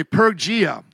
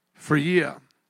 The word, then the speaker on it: Phrygia: Now, I would normally say Pergia. But it is not Pergia, and it is silent. It's like an F here. Phrygia. Something like that. What are you going to say? Phrygia. It's an F sound, like phone there.